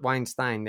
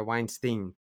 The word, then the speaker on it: Weinstein: I should have known this given the amount that I've engaged with their contact, but they are not Weinstein, they're Weinstein.